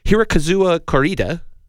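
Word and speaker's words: Hirokazu Kore-eda: Hirokazuwa Koreeda. – Wow. – Is it close, Steve? – That's fairly close.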